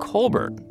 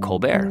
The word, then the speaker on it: Colbert: His family pronounced their last name Colbert, the Irish way, but Stephen's dad told the kids it was up to them how they wanted to pronounce it. So right before he headed off to college, Stephen Colbert decided to become Stephen Colbert.